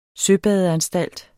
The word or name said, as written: søbadeanstalt